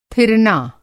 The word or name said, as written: थिरना